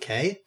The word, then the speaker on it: Kaye: People that did get in this movie, however, uh, we had David Kane. I believe that, that's how you say his name. Uh, the the voice of Megatron, Beast Wars. David Kaye. K is K.